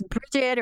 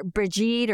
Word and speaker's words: Brigid: It's Bridget or Brigid or Bride. It's said many different ways, but Bridget most commonly, I think.